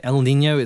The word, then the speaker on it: El Niño: El Niño, are we saying it correctly? – Yes, that's right. El Niño, it's a naturally occurring weather phenomenon that happens every few years.